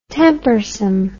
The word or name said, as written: tempersome